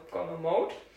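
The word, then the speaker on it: GommeMode: Und zwar ist es Aussprache Gomme, Eigenname deutsch ausgesprochen, und Englisch Mode, also von Modus, also Gomme Mode.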